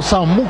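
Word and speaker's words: Samú: Samu Costa es porque lleva tilde en la U.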